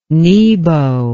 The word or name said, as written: nebo